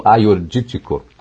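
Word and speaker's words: Agiorgitiko: Aiordítico é com G, Agiorgítico, mas eles pronunciam Aiordítico, que dá um vinho tinto, muito interessante, frutado, lembra um pouco o Syrah, e inúmeras outras, viu?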